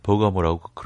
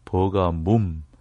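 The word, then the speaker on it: Pergamum: 오늘날 터키에 가보면 버가모라고 그렇게 발음하지 않고 버가뭄, 뭄이라고 모에다가 미음 받침을 하나 더 붙이는 거죠.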